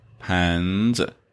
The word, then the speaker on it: 盘子: And the second one, 盘子. pan is second tone, so the neutral syllable here 子 is also lower. 盘子.